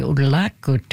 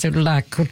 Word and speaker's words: Udlakut: So if you want to say in Inuktitut, you can say Udlakut to all the audience here in the north. – Can you say it one more time? How do I say it? – Udlakut. – Ugalaktuk? – Yes, Udlakut.